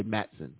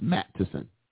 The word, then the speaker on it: Matteson: Or I'm sorry, let me say, is it Matteson? I think it's Matteson. because we always say Mattson, but I think it's pronounced Mattison.